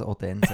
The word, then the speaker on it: Odense: Ist ja klar, dass es Odense ist.